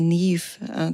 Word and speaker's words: Niamh: Oder der Name Niamh, das heißt Licht und das schreibt man N-I-A-M-H. – Oh. – Niam. Und das schreibt, sagt man Niamh.